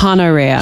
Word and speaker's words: Honoria: But also they make a point about how the H isn't silent in the book. – Honoria.